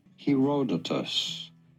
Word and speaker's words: Herodotus: Herodotus.